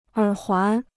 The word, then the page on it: 耳环: ěr huán